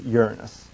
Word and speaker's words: Uranus: And due to a frequently used but incorrect pronunciation, this planet is the butt of many jokes. The correct pronunciation is Uranus.